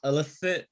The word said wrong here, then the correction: Alanis: Yes, Alanis.